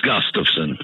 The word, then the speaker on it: Gustafson: It's Gustafson.